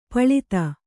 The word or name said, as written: ಪಳಿತ